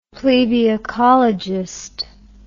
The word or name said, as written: plebiocologist